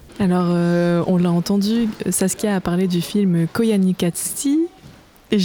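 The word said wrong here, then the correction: Koyaanisqatsi: Eh bien écoute, Koyaanis Katsi, qui s'écrit du coup K-O-Y-A-N-I-S-Q-A-T-S-I, Koyaanis Katsi, Imbattable au Scrabble rang.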